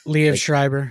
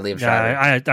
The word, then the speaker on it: Liev Schreiber: Liev Schreiber. – Yeah, Liev Schreiber.